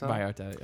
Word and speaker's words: Vallarta: Vallarta, yeah.